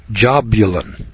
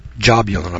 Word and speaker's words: Jahbuhlun: See if I can remember how to pronounce this one. It's spelled J-A-H-B-U-H-L-U-N. Jabulun. Jabulun, I believe it is.